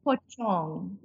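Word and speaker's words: pocong: Pochong. – Pochong. – Yeah, whenever you see a C in a Bahasa Melayu word, they pronounce the C as a ch. – Okay. – So it's pochong.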